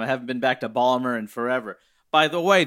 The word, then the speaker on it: Baltimore: Yeah, if you pronounced every letter, yes, that would be the pronunciation, but it's Ballmer.